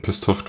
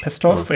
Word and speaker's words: Pestov: Groff und Slava Pesto, ich weiß nicht, ob ich ihn richtig ausgesprochen habe, ähm, Pestov durch. – Pestov, ja.